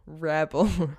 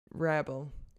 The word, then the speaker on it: Wrabel: Rebel. Ich habe mir das 10-mal auf Google Übersetzer angehört, die beste Quelle zum Aussprache von Wörtern zu finden oder zu erkunden. Und Rebel. W-R-A-B-E-L.